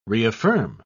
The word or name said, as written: reaffirm